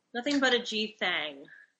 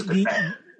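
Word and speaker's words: Nothin' But a G Thang: Have I been pronouncing it wrong? – Nothing But a G Thing. – I thought it was good thing. – The title proper says thang.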